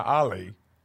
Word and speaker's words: Ali: Tatiana Ali.